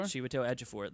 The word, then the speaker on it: Chiwetel Ejiofor: But I feel like that trio, Tilda Swinton, Benedict Cumberbatch, and I'm going to mispronounce his name. – Chiwetel Ejiofor? – Chiwetel Ejiofor. There, thank you. Years to get that right.